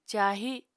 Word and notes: cahi: apatani

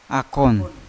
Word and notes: akon: Yawa; Unat

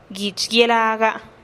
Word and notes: guich guielaga: TdVZ

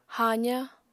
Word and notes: haaza: Apatani

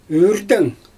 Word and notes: yrtɛng: Xyzyl

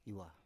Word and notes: iwa: Biak

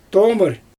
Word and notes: toomɯr: Xyzyl

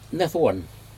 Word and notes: nedwun: Aneityum

